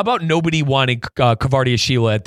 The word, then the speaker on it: Kvaratskhelia: Kvaratskhelia. – Yes. – Oh, my God. – I'm glad you pronounced his name. – Yeah, Kvaratskhelia.